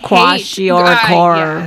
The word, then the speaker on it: Kwashiorkor: I Kwashiorkor.